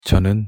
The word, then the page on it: 저는: Korean